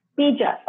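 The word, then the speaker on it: bīja: B-I, long I. B or B-double-E. You can think of it as B-double-E. Bija. But it's a long I. The I with the line over it.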